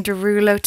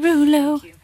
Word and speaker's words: Derulo: Now we're giving away Jason Derulo tickets. – That's not how you say it. – Oh, sorry. Jason Derulo.